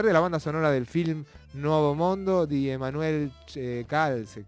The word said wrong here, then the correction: Crialese: ¿cómo se pronuncia? – Crialese.